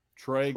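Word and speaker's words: Tröegs: Is that how you say that, Trox? How do you say that? – Troegs.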